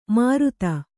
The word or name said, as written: ಮೂರುತಿ